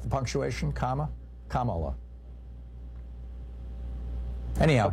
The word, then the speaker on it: Kamala: I've heard mispronounce it on Fox News, and Trump mispronounces it as a way of slurring her. It's Kamala.